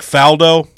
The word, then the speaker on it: Faldo: Nick Faldo, is that how you say his name? – Yeah.